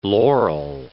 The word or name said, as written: laurel